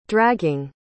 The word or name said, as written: dragging